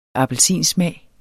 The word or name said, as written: appelsinsmag